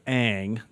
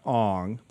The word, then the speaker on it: Aang: And the character's name is Aang, not Ong. They changed the name, the pronunciations for the movie for some reason.